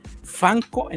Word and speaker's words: Funko: Entonces es una sigla de fan corporation y de ahí por eso se llaman Fanco en inglés, pero se escribe funco Para nosotros siempre será Funko.